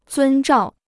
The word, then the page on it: 遵照: zūn zhào